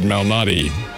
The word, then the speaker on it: Malnati: Let's see, he pronounces it Malnati.